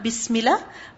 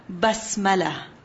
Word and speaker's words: Basmala: Yes, I said it right. Not Bismillah, Basmala.